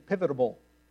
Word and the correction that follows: pivotal: That's not a word.